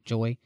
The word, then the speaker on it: Joi: To me, it almost seems like mirrored with Kay and Joy. Is that how you say her name? J-O-I, Joy.